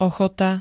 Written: ochota

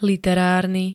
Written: literárny

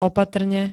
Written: opatrne

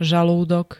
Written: žalúdok

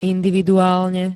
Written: individuálne

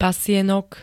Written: pasienok

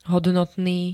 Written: hodnotný

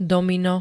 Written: domino

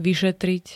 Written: vyšetriť

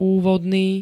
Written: úvodný

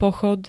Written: pochod

